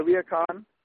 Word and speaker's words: ryokan: Like a ryokan? – Yes, that's how you say it.